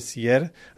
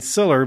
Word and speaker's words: Siller: With me in the studio is Archbishop Garcia Siller. I've been saying Siller, but it's really Siller when you put the Spanish proper Spanish pronunciation on that.